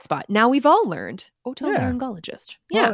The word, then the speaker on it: otolaryngologist: It is otolaryngologist. – Otolaryngologist. – That's, yeah. So I wasn't sure if it was like a hard G in the first G, but it is otolaryngologist, unless the pronouncer videos have lied to me, which is possible.